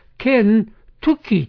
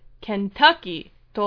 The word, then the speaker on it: Kentucky: タッキーといえば、やはりケンタッキーフライチキンですかね。クリスティンお好きですか？ – はい、好きです。子供の頃からよく食べてました。 – まず、ケンタッキーのスペリングですけれども、 K-E-N-T-U-C-K-Y、そのまま読みますと、ケン・トゥキってなるんですが、ケンタッキーと読みます。